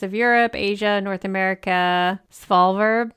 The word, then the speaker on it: Svalbard: How do you say that? S-V-A-L-B-A-R-D? – Svalbard.